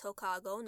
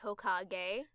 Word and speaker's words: Hokago: And yes, it's Hokago, not Hokage. – Hokage. – People have been telling me, you're saying it wrong. I'm like, no, it's spelled this way, and you say it this way for a reason.